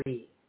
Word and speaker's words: Lee: I was named Deborah Lee. Actually, Deborah Lee. When I was born. – International shout out to Rachel in Australia. Go ahead. – But the Lee is spelled L-E-E.